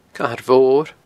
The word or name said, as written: Cathair Mhor